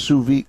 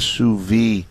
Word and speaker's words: sous vide: It's pronounced sous vide. The D is near silent, and there's no on the end of it. Sous vide.